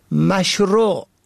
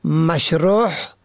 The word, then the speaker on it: مشروع: به گونه مثال مشروع که معنای آن آنچه مطابق شرع است را شماری مشروع یا مشروح به حلقی تلفظ می کنند که شرح شده معنا می دهد.